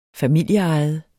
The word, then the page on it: familieejet: -ˌɑjəð; -ˌɑjˀəð